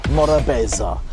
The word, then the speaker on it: morabeza: and it's pronounced Morabeza, an all-encompassing term referring to the importance of hospitality, friendliness, and most importantly, enjoying life in a laid-back and calm manner.